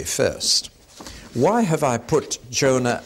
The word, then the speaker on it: Jonah: We're going to look at Jonah and Nahum, or as they should be pronounced in Hebrew, Yonah and Nahum.